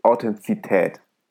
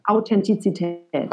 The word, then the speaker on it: Authentizität: Ich glaube, der größte Faktor ist, denke ich, Authentizität. Habe ich das richtig ausgesprochen? Ja, ne? – Authentizität. – Habe ich nicht richtig ausgesprochen, genau.